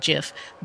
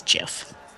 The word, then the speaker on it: Jif: When I had looked and read and listened to the actual inventor or for that name years ago, he had said that it was pronounced with the J type of sound, Jif, but that it really didn't matter.